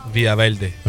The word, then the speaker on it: Villa Verde: How do you say Villa Verde? – Villa Verde. – I like that too. – Because that two L's makes a Y.